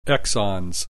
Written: exons

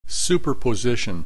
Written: superposition

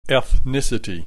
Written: ethnicity

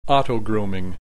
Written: autogrooming

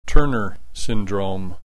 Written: Turner syndrome

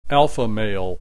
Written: alpha male

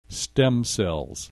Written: stem cells

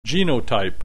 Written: genotype

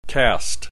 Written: caste

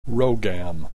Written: Rho GAM

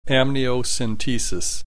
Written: amniocentesis

